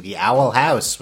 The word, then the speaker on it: The Owl House: No, it's just like, it was just the owl emphasis house. On house.